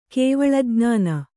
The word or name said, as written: ಕೇವಲಜ್ಞಾನ